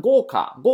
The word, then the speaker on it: 豪華: 豪華，豪華的话是豪华，对吧？哎，豪读成豪，华读成卡啊。豪華，那豪华、奢华的。你看那个泰坦尼克号的话，是不是就可以算得上是豪華了？对吧？哎，很豪华的一个什么呀？船啊，客船，客船，豪華客船，就是豪华客船。